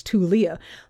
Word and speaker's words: Tulia: But if you live in this tiny town in the panhandle of Texas, you pronounce it tool, like a hammer is a tool. Yeah. – Tulia. – Tulia. – That's right. – Tulia. It's a two-syllable word, Tulia.